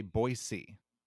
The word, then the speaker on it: Boise: Doing research on Boise, I found out that this is not how locals pronounce it. They say Boise, Boise? with an S. – Mm-hmm, Boise. – Boise. – We all say Boise with a Z sound.